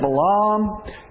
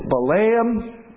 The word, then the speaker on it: Balaam: The land of Canaan. Same word is spelled by a gentleman's name spelled similarly. It isn't Balaam. It's Balaam. It's like Canaan, Balaam. Okay? So get that in your heads, all right? It's Balaam, not Balaam.